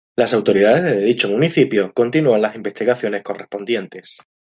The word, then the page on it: correspondientes: spaans